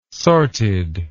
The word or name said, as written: sorted